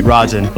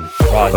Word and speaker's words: Rajan: Like, it sounds like R-A-J-I-N. Rajan.